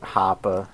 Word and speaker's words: Hoppe: however the fuck you pronounce his last name, Hoppy. Yeah. – Hoppe.